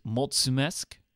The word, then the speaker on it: mulțumesc: So, like for example, thank you in Romanian is mulțumesc. That's how you say it,